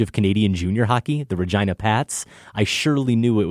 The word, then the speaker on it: Regina: Regina, Saskatchewan. The name of that city isn't pronounced like the woman's name, Regina. The I is pronounced I, not E. A helpful pronunciation guide. It rhymes with vagina.